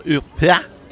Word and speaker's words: Huppert: I'm going to butcher her French last name. Huppert. Yeah, um, sure. yeah. – Oh, you mean Isabelle Huppert? – oh, you put me to shame.